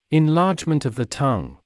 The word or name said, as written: enlargement of the tongue